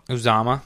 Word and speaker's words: Uzomah: Uzomah.